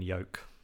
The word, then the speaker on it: yoke: Jazza, what celebrations do you have planned for the glorious 1st of January when we're finally free from the European yoke? I thought I delivered that quite well, actually. – European yoke. – That's Y-O-K-E, like rhymes with woke rather than, you know, the egg thing.